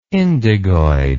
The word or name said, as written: indigoid